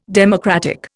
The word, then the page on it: democratic: Indian